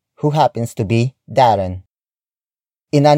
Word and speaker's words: ina: It is the focus marker, which introduces the main noun of this sentence, which is ina. Ina. Ina. Ina is spelled as I-N-A.